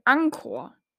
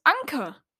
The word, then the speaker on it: Anker: Viele Leute sagen Anchor oder An Anchor. Das ist falsch, das heißt Anchor und es heißt Anker. Anker heißt Anker, ja.